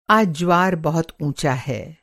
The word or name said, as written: ज्वार